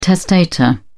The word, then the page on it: testator: British English